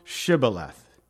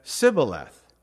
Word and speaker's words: Shibboleth: And if he said, "Nay," then they said unto him, "Say now, Shibboleth." And he said, "Sibboleth," for he could not frame to pronounce it.